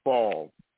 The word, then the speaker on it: Favre: That's his name. The ex-football player, Brett Favre. Yeah, no. – Oh, okay. Thank you. I wasn't saying his name correctly.